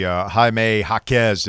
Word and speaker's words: Jaime Jaquez: Getting the Jaime Jaquez, did I pronounce that correctly? – You did.